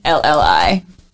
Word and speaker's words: Marcarelli: Marcarelli.